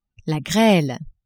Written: grele

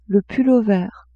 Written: pull-over

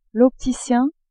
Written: opticien

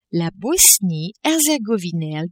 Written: Bosnie-Herzegovine

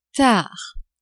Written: tard

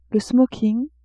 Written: smoking

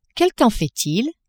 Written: quel temps fait il

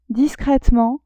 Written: discretement

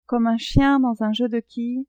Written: comme un chien dans un jeu de quilles

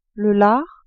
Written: lard

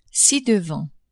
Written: ci-devant

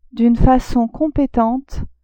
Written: d'une facon competente

